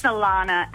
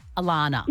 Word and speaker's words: Alana: It's Alana. And I get double N-A-H. I get every letter of the alphabet put at the end of it, but it's just A-L-A-N-A.